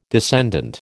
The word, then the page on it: descendant: American English